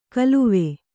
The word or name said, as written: ಖಿಲ್ವ